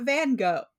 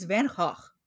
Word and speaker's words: Van Gogh: It's not Van Gogh. It's Van Gogh. It's not. – Oh, because it's Dutch.